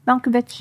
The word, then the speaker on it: Malkovich: Malkovich? – Malkovich.